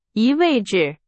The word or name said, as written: 移